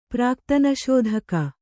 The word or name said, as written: ಪ್ರಾಕ್ತನಶೋಧಕ